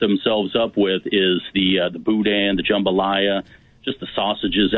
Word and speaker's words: boudin: Well, and by the way, boudin is a silent N.